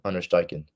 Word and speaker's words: Steichen: And I really, really hope to see that continue under Steichen. Right name, right? Is that how I pronounce it? Yeah.